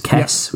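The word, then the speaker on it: Kes: One is obviously Kez, which he pronounces Kess, which I thought was quite fun.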